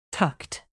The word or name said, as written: tucked